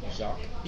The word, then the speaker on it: Jacques: How do you say it again? – Jacques. – Jacques, okay. Jacques de Saint Germain.